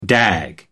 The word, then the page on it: dag: American English